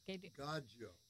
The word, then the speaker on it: Gaggio: Now, how do you say that word? – Gaggio. – Gaggio.